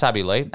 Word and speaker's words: Abby Lee: Is it Abby Lee? Did I say that right? Or have I got that wrong and got my wires crossed again? No, it's Abby Lee.